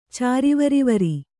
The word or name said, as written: ಚಾರಿವರಿವರಿ